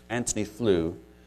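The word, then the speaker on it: Antony Flew: I saw an interesting quote here recently from Anthony Flew, and it is pronounced that way. He's a British professor, but his name is spelled Anthony, A-N-T-O-N-Y. He's taken out the H there.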